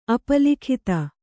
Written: ಅಪಲಿಖಿತ

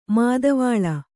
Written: ಮಾದವಾಳ

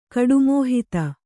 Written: ಕಡುಮೋಹಿತ

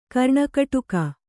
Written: ಕರ್ಣಕಟುಕ

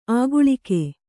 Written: ಆಗಳಿಕೆ

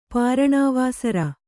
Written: ಪಾರಣಾವಾಸರ

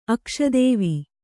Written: ಅಕ್ಷದೇವಿ

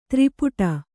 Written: ತ್ರಿಪುಟ

